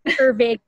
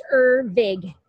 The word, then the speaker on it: Ervig: Ervig. I know that tough time. It's Ervig.